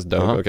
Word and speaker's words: Dogue: Dogue that it launched out in San Francisco. You say it like Vogue, yeah. like Vogue, this Dogue, uh-huh.